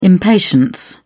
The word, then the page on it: impatience: British English